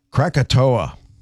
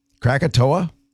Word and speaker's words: Krakatoa: Krakatoa. Krakatoa in Indonesia, 1883.